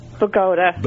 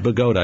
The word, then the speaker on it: Bogota: How do you pronounce the name of your town? – Bogota. – Bogota. I said it right. Okay. – Yes, you did.